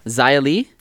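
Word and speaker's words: Xia Li: Zaya Lee? – Yes.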